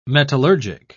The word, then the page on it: metallurgic: British English